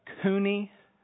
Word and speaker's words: coney: It's actually the coney, I think is how you pronounce it, C-O-N-E-Y, according to the NIV.